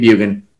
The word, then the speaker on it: Bjugan: So Cody Bjugan, the J is silent when saying Bugan.